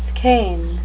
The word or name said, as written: skein